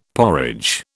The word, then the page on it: porridge: British English